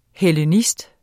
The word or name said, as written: hellenist